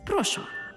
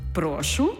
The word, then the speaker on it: прошу: «Прошу?» Ну, якось так. Тож я прошу задягати вашу ошатну українську у це слово, але не плутати його з дієсловом «прошу» з наголосом на останній склад.